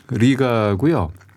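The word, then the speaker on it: Riga: 라트비아의 수도는 리가고요. 리가라고 했을 때 이거 발음을 뭐 L 발음이야 R 발음이야 고민하실 필요 없습니다. 우리 하면 편하시게 하시면 R로 시작이 되기 때문에 예, 그냥 리가 이렇게 발음하시면 되고 네.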